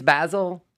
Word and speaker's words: Basel: Is that how you say it, or is it Basel? – It's Basel. I always called it Basel, but apparently when I got down there, they were like, it's Art Basel.